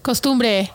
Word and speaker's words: costumbre: Costumbre? How do you say that? – Custom? – Is it? There's another word I know. – Yes, Costumbre. Ido's gonna know it. – Costumbre.